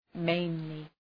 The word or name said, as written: mainly